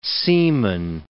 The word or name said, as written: seaman